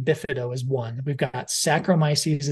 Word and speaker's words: Saccharomyces: The second category is Saccharomyces. I can actually pronounce that one. So that one's easy. So whenever you see sacro, and S-A-C-C might be an H-R-Y, something like that.